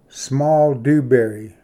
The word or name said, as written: ᏬᎴᏓ ᎤᏁᏍᏓᎵ ᎤᏅᏌᏓ